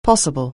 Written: possible

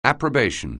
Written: approbation

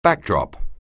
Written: backdrop

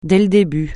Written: dès le début

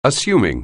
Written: assuming

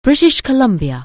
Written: British Columbia

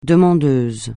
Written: demandeuse

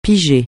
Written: piger

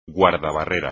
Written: guardabarrera

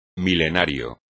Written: milenario

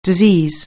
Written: disease

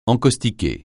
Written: encaustiquer

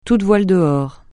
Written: toutes voiles dehors

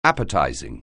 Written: appetizing

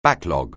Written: backlog

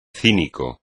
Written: cínico

